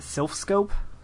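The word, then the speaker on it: Silph Scope: Anyways, I was in Celadon, and I just got the, the Sylph Scope? Is that how you pronounce it? – Yeah.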